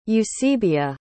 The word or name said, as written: eusebia